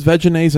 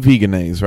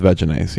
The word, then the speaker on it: Vegenaise: And we're sure this is Veginaise and not Veganaise, right? – Well, no. – As far as pronunciation. No, the second letter is an E, so I feel better with Veginaise, yes.